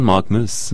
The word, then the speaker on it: Malkmus: Steven Malkmus.